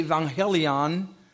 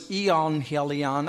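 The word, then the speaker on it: euangelion: The word in the Greek has a U in it. It's hard to pronounce. If I put the V, I could say evangelion. But there's a U, so it's eonhelion. I can't pronounce it.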